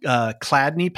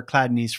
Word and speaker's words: Chladni: And these are called Cladney patterns. And this is this guy. I can't remember where Cladney's from. There's a whole Wikipedia article on it. It's spelled C-H-L-A-D-N-I.